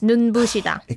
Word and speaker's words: 눈부시다: 눈부시다. I find this hard to say because the biup here, it's not pronounced as like a sang biup, right? – Mm, no. But it's 눈부시다.